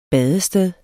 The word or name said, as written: badested